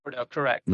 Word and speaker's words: Novus Ordo: But when I was a Catholic and I was an altar boy participating in the new mass, Novus Ordo, is that how you say it? – Ordo, yeah. – Or Novus Ordo. – Ordo, correct.